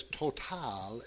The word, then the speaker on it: Total: And the reason why you could say it as Total is because it's from France.